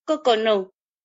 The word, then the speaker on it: Coconó: ¿Cómo se pronuncia, este, tu restaurante? Es que yo lo pronuncio como en, español y como en náhuatl, que es mi lengua, pero seguro eh, en Tzotzil eh, tú lo pronuncias distinto. – Es Coconó.